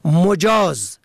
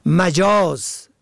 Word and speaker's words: مجاز: مجاز به ضم اول، اجازه داده. مجاز به فتح اول، غیر حقیقی که در تلفظ آن باید دقت شود.